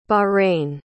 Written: bahrain

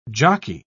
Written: jockey

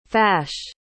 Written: fash

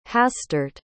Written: hastert

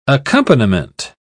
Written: accompaniment

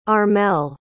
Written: armelle

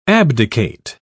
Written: abdicate

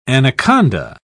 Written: anaconda

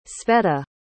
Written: sveta

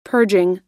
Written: purging

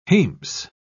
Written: hames

